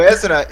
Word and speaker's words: Ezra: Como é que pronuncia essa Ezra. Ezra. Ezra. Ezra. É, Ezra. Ezra Bridger.